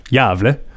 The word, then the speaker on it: Gävle: There's a place in Sweden, which is, I believe, I've checked, pronounced Gävle. – Very good.